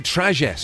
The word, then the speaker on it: Trajet: So if your car rhymes with tragic, you're in trouble there. – Hyundai Trajet.